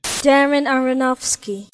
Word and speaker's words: Aronofsky: I think that kind of feeds into Aronofsky. Am I pronouncing that right? – Darren Aronofsky.